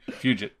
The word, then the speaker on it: Fugit: Fugit.